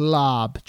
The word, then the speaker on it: larb: But this chicken larb, it's spelled L-A-R-B, but it's pronounced lab, chicken lab.